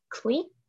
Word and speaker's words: clique: I think I'm saying this correctly. I don't know. Click. Okay, that's how you say it. The QU throws me off.